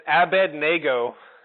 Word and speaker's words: Abednego: it's Abednego. The N comes after the D, right?